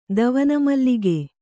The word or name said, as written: ದವನಮಲ್ಲಿಗೆ